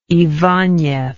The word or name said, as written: ibanez